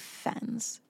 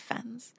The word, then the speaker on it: Fiennes: Now, the guy who played Voldemort, his name looks like it's Ralph Fiennes, but I looked up how to pronounce it and it's like the opposite of safe. It's like Ralph Fiennes. Ralph Fiennes.